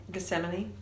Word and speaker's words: Gethsemane: Gethsemane. – Just like same.